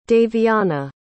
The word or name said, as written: davianna